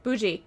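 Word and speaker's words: Boujis: It's B-O-U-J-I-S, Boujoux. – Bougie.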